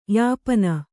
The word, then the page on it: ಯಾಪನ: Kannada